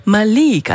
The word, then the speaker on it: Malik: Malik, also Betonung liegt auf der zweiten Silbe, Malik Harris.